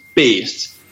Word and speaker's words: BAEST: But yeah, it's the Danish derivement of beast or brute, and it is pronounced BAEST. We would probably spell it like BASED or something.